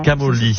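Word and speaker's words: Camogli: On prononce pas le G. Camoli.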